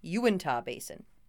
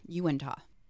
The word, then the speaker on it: Uintah: Uintah Basin. – Okay, Uintah.